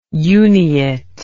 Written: uniate

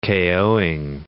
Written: KO'ing

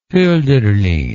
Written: holderlin

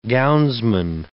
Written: gownsman

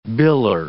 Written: biller

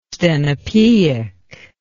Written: stenopeic

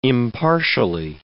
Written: impartially

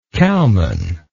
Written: cowman

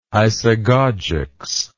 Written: isagogics

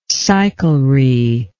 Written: cyclery

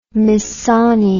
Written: Missoni

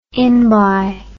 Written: inby

